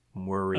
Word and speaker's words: Muire: So, Muri Lowe, I don't actually know how to pronounce this. M-U-I-R-E. Muri. – Oh, the assistant. – Muri Lowe is her secretary. Yeah. – Uh-huh. Muri. – Muri. That sounds good.